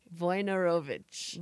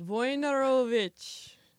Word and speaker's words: Wojnarowicz: Joey wrote us a note about the pronunciation, because Joey's very, very Polish and can read the alphabet. So it's Wojnarowicz. – Wojnarowicz.